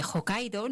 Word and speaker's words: Hokkaido: Igual que se desconoce otro perfil de islas que realmente están siendo emergentes y de hecho a la de Hokkaido, no sé si lo he pronunciado bien, perfecto, se cruza en túnel ferroviario submarino, que es el más profundo del mundo.